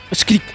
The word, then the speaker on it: Achkirik: voy a ver si lo digo bien ya, Achkirik ¿Está bien? ¿Bien dicho? Achkirik Productora Valeria, lo dijo muy bien.